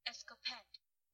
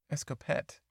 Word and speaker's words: Escopette: Midway across the channel was a French destroyer called Escopete. Ah, the French. I just don't know their language. I finally got Wooster right, though. Does somebody want to give me a little credit for Wooster? Pronunciation. It's just so embarrassing. – Escopette. – Escopette.